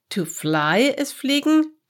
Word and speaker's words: fliegen: IE is a long E in German. Let me say it to you. Wenn fliegen hinter fliegen fliegen, fliegen, fliegen, fliegen nach.